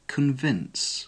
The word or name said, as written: convince